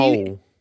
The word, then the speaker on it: Powell: P-O-W-E. – Pole. – It's Powell. It's P-O-W-E-L-L. – That's pronounced pole.